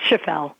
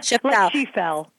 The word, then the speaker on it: Schaffel: Did I say your name wrong? Is it Schaffel? – It's Schaffel. Schaffel. Yeah, no, that's my bad.